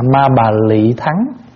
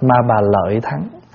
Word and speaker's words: ma bà lợi thắng: Thí dụ như mình đọc là cái câu cuối, Na ra cẩn trì bàn đà ra dạ ta bà ma bà lị thắng, nhưng mà sau này mình đọc ma bà lợi thắng.